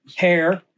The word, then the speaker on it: heir: What is, some people say heir, we say heir, silent H.